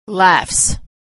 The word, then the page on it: laughs: ending s